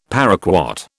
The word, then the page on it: paraquat: British English